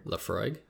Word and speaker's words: Laphroaig: Laphroaig.